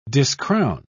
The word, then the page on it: discrown: British English